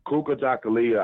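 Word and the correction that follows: Kujichagulia: It's pronounced Kuji Chakalia. – Kuji Chakalia. – Chakalia.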